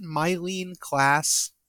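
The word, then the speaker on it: Myleene Klass: Is it Mylene Klass? – Yeah, that's it.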